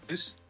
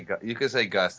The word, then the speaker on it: Guus: It's a Dutch name. You say it like Guus? – Guus? – Yeah, but you can say Guus. That's much more easier.